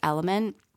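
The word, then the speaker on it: LMNT: I think it's pronounced Element.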